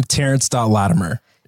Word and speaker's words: Terence Latimer: It's just my name, Terrence.Latimer. – Yeah. And can you spell that for us? – TERENCE.LATIMER.